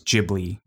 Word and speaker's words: Ghibli: But the way to pronounce it for the studio is Ghibli with a J sound.